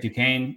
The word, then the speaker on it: Duquesne: Is it Duquesne? – I think it's Duquesne, right? – Duquesne, yeah.